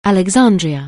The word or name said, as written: Alexandria